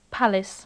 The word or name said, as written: palace